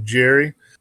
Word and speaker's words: Gerrie: So, do you know how to pronounce Jim's last name? – Jim O'Keefe? – No, Jim, the next line item here, Jim. – Jim Jerry, I thought it was. – Jim Jerry, G-E-R-R-I-E, is someone we see in the Coco community.